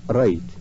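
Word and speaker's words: write: في الإنجليزية مثلا فالواو W قبل الآر لا تنطق، مثل رايت يكتب، ومثلها البي والإتش والإن واليو في كثير من الكلمات.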